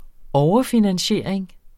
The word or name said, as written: overfinansiering